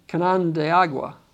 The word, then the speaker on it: Canandaigua: It actually, Canandaigua.